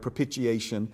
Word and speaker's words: propitiation: So that, you know, those of you who take notes and maybe looking for the spelling on that, if you've ever heard someone say propitiation, they're not saying it incorrectly. It's one of those words that can be pronounced two different ways. I tend to hit that first T kind of hard, propitiation. But most importantly, what does this word mean?